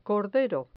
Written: cordero

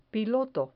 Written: piloto

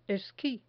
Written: esqui

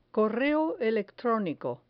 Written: correo electronico

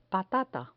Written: patata

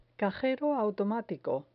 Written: cajero automatico

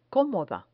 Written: comoda

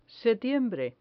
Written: setiembre

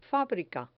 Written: fabrica

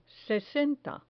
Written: sesenta

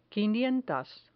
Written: quinientas

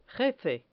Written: jefe

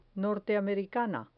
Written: norteamericana

